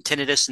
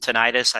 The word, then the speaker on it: tinnitus: And tinnitus and tinnitus, I think it's both are acceptable pronunciations.